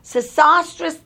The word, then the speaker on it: Sesostris: During the 12th dynasty, at the beginning, there are two great pharaohs, Sesostris II and Sesostris III. S-E-S-O-S-T-R-I-S, that's the way it's pronounced.